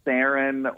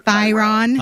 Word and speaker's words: Theron: The H is silent. – Theron. Yeah. – We got to remember that. Like the Integratron. – You know how you remember Theron. – It's like, think of to run, Theron.